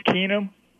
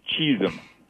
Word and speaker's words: Keenum: Case Keenum, you know, I have to say this. I feel for the guy because John Elway mispronounced his name. Case Cheesum.